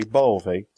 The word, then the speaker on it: Bove: It's technically neither, because it's Italian, so it's actually Bove. But Bove is kind of what people can say. But I accept all variations thereof.